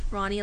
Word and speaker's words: Ronnie: It's spelled as Ronnie, like R-O-N-N-I-E, and then L-Y-N-N.